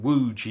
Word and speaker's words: Łódź: I mean, it's spelled L-O-D-Z, but and Łódź is the...